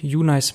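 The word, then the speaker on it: uNAICE: Da wird nämlich geschrieben kleines U, großes N und dann A-I-C-E, ausgesprochen UNICE.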